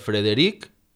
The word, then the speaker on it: Frederic: Finalment, cal tenir en compte que hem de pronunciar la R de Frederic i orquestra, i que la paraula xarampió va amb una R, no és xarrampió.